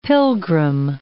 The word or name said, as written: pilgrim